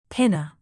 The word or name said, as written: pinna